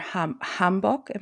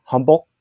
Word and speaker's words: hanbok: Yeah, it's the O is almost like a O. It's like a rounder O. Hanbok. Bok. Like hanbok. – Yeah, but not like, you don't have to overthink it. But it's like a hanbok. So it's a little more rounder O sound.